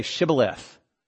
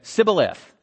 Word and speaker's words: Shibboleth: They say, you've got to say Shibboleth. because the Ephraimites couldn't pronounce it correctly. They would say Sibboleth instead of Shibboleth because they couldn't pronounce their sh.